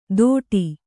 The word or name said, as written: ತೋಡಿ